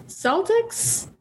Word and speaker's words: Celtics: This strip is taking place at theoretically TD Garden, which is an arena in Boston where the Bruins play and also the Celtics? – The team is pronounced Celtics, which is not how you pronounce that word in any other context that I've ever heard.